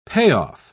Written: payoff

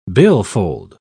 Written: billfold

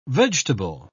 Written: vegetable

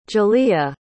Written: jaliyah

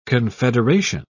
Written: confederation

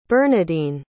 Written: bernadine

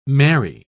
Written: merry

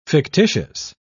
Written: fictitious

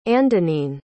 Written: andonine

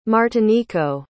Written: martinico

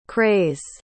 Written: crase

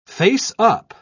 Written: faceup

